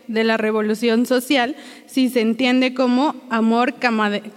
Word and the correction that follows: camaradería: Camaradería. Camaradería. Hasta lo anoté así para que no lo fuera a pronunciar mal y lo pronuncié mal.